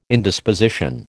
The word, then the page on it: Indisposition: American English